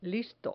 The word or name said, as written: listo